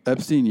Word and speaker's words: Epstein: Epstein, yeah.